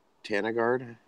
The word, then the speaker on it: BotaniGard: But so, like, her thing, she thinks Botanigard, am I saying it right? Yeah, Botanigard is one of the safest products out there, and it's the only product that people can use that's safe for root aphids.